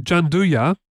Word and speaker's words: Gianduia: Yeah, theirs is reportedly called Gianduja, which is an Italian word, actually. But it's spelled Gianduia for those who want to search for it.